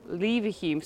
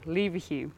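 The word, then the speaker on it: Leverhulme: Later Lord Lever Hume. Sorry, I said his name wrong. Lever Hume.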